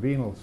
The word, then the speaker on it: venial: How do you say this? Pardon? – Mortal sins and venial sins. – Yeah, mortal sins and venial sins.